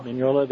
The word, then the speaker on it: Mignola: Mignola, there we go.